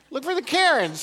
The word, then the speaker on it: cairn: It, by the way, it's spelled C-A-I-R-N. And it is a stack of rocks that marks out the trail.